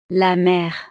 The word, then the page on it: la mere: frances